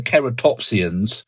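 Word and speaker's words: Ceratopsians: Triceratops was a member of another group of dinosaurs, the Keratopsians. I'm pronouncing my C in a classically approved way there.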